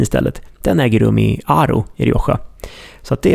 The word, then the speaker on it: Haro: Och här uppe i eh, Rioja Alta så ligger också en by som heter Aro. Alltså H-A-R-O, Haro, men uttalas Aro.